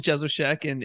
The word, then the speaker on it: Jesowshek: Yeah, Jerry, last name is Jesowshek, and you wouldn't be the first person to mess it up, so don't worry about that.